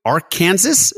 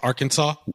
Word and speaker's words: Arkansas: Ark-ansas is number three. – Arkansas. That's Arkansas. – Did you just say Arkansas? – Seriously? – Why do we pronounce Arkansas the way we do instead of saying Ark-ansas? – I mean, I get it.